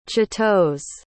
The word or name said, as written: chitose